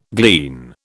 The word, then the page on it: glean: British English